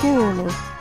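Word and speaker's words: gurulhu: is Gorlo.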